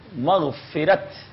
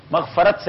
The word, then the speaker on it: مغفرت: مغفرت یہ صحیح ہے، مغفرت صحیح نہیں ہے. ٹھیک ہے، مغفرت صحیح ہے.